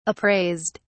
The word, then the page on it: appraised: British English